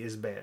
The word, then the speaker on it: Isbert: Tony Isbert was in Tragic Ceremony, but I'm pretty sure that it's definitely got to be Isbert. I do not think the T is pronounced.